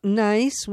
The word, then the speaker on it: gneiss: And you spoke about NICE, which is spelled G-N-E-I-S-S,